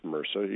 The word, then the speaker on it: MRSA: It's a all capital abbreviation, MRSA, pronounced MRSA, you're right.